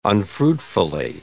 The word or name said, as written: unfruitfully